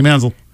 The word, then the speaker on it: Manziel: That's how he pronounces it. Johnny Manziel.